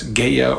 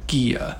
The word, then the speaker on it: Gaia: That sound, Anu, appears in both names for the sky, and there is also a clear linguistic relationship between Ki and Gaia, which has alternate pronunciations as Gaia or even Gia.